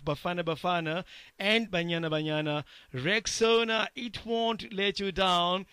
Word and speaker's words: Rexona: A friend of mine even called it Rexona. But guys, come on, it's Rexona, like Rex and the owner, Rexona.